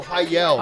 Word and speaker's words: Heil: Special thanks to Heil Sound. Heil Sound. – Oh, there it is. Oh, Heil.